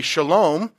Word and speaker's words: Salem: And Salem, if you would want to pronounce it more accurately, would be Shalom, and that is Hebrew for peace.